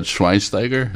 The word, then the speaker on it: Schweinsteiger: Yeah, I mean, like last night, Bastian, I'm not going to say his last name right, but Schweinsteiger, is that right? The soccer guy? – Oh, yeah. I think you said it pretty close. If it's not right, you missed it by a half a syllable.